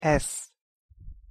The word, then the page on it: sz: Hungarian